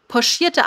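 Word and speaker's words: pochiert: äh, und äh, da hat äh, der Utrecht geschrieben: Poschierte Eier spricht man mit einem Sch und nicht mit einem Ch. Oh, auch wenn es mit aber, Ch geschrieben wird.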